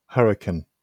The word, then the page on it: hurricane: British English